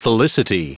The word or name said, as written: felicity